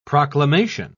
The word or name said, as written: proclamation